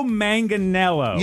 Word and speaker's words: Manganiello: Joe Manganiello. – Yeah, you got it. – Joe Manganiello.